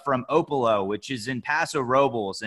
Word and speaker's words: Paso Robles: And yes, I pronounced that correctly, Paso Robles. A lot of people think it's Paso Robles. That included me until I actually moved to the Central Coast, and I was quickly acquainted to the fact that I was pronouncing it incorrectly, although, yes, the Spanish pronunciation, you would think it'd be Robles.